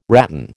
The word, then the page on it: ratan: American English